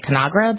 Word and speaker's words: Conagra: This is, I can't even say this right, Conagra Brands, Inc., C-O-N-A-G-R-A.